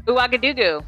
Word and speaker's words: Ouagadougou: Ouagadougou.